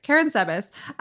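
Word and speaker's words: Karánsebes: So the Battle of Karen Sebas. Is that how he said it? Do you remember? – Yeah, it is. – Okay, great. Karen Sebas.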